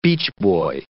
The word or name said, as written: beachboy